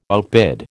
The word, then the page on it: outbid: American English